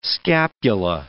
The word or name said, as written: scapula